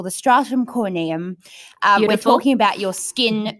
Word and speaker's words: stratum corneum: I love that you've just said barrier because sometimes there's a few different terms floating around like we hear, how did you say it? Stratum corneum. – Yes. Excellent. – Stratum corneum.